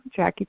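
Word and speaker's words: Jacci: You can pretty much find me anywhere on social media under my name, but my name is spelled really weird. It's J-A-C-C-I Turner, Jackie Turner.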